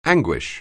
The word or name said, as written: anguish